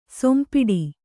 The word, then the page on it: ಸೊಂಪಿಡಿ: kannada